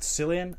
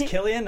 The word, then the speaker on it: Cillian: It's pronounced Cillian, not Cillian. I never. – You say you agree? – I agree with you, yeah. I do agree. – The word's out. It is Cillian.